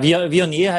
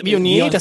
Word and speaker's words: Viognier: Ja, Viognier heißt das. – Viognier? Viognier. Das ist Viognier.